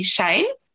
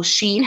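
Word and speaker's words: Shein: Well, of late, it has to be Shane or Sheen, however you want to pronounce it.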